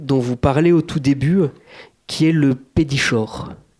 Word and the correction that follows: pétrichor: D'ailleurs, dans le dernier tirage, vous n'avez pas le dernier tirage, j'ai rajouté la prononciation parce que c'est un CH euh, venu d'une racine grecque, donc c'est le pétricor.